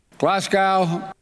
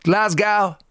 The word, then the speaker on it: Glasgow: But I didn't hear this, probably wasn't paying attention, but during COP26, both Joe Biden and Barack Obama mispronounced Glasgow. So this is Mr. Obama. – Glasgow. – Right? – Glasgow. – It's Moscow. And then Joe Biden. – Glasgow. – Glasgow. No, it's Glasgow. Glasgow.